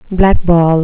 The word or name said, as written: blackball